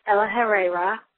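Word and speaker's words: El-ahrairah: Yes, that's how you pronounce his name, which I only know because it was in the Mark's Down book, which I don't recommend so much. It wasn't very good. That's El Ahreira, which I pronounced completely wrong in the movie.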